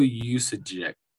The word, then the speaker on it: Juszczyk: Kyle Juszczyk.